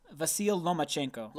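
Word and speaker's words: Vasyl Lomachenko: Leronchenko, what? How do you say his name again? – Vasil Lomachenko.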